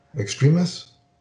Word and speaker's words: Extremis: And my comic guy gave me the Warren Ellis Extremis. Is that how you pronounce it? – Yes. Yeah.